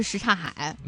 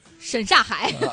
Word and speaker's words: 什刹海: 所以我们真的要给大家普及，一个是这个大石烂，一个是什刹海。 – 嗯，什刹海会读成什么呢？ – 沈刹海。